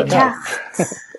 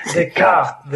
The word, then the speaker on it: Descartes: Och detta är då en fransk filosof som heter Descante. Descante, jag vet inte hur man uttalar det. Descartes. – Descartes, Descartes.